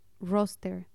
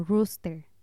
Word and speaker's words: roster: Otra palabra que me costó mucho trabajo al inicio fue roster, que significa lista. Y acá se usa mucho para referirse al horario que te mandan con la asignación de turnos. Y yo la pronunciaba como rooster, que es gallo.